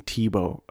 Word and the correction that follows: Teba: Not Tebow, Teba. Tim Tebow. Teba. But it's pronounced Teba.